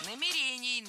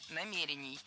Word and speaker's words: намерений: Сказали, что у нас нет никаких намерений предыдущего руководства. – Намерений, намерения – это неверно. Правильно, намерение, намерений. Ударение в этом слове ставим на второй слог и не меняем это ударение при изменении слова.